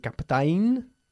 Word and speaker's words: captain: se escribe captain, pero se pronuncia captain, okay? Olvidemos la A, la segunda A, okay?